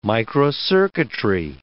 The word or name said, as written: microcircuitry